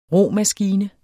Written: ROMASKINE

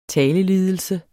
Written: talelidelse

